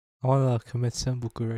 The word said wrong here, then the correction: seppuku: Is it seppuku or senbuku? There's no M in seppuku. – Senbuku. – It's seppuku, right? – I just want to kill myself, basically.